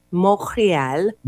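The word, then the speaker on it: Montréal: Montreal. Montreal. You don't say the T. Montreal.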